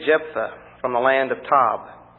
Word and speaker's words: Tob: So Jephthah fled from his brothers and lived in the land of Tob. Well, we can pronounce that according to the Hebrew, Tov, which means good.